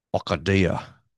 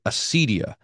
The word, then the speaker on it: acedia: Acadia is the, or Acadia, Here, I always think about how to pronounce this word because I learned it mostly from Dr. Kleinig, who says it in Australian. Acadia. And no, most Americans say acedia, but this is helpful to have the Greek here because that's not a C, that's a K.